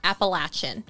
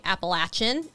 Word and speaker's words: Appalachian: And she had said in an Instagram story, this is where I was following her, she was saying that people miscommonly pronounce Appalachian, and that it's technically Appalachian. And she's like, and it drives us a little batty. And she's like, I know it sounds funky, but if you're over here, people will want you to say Appalachian.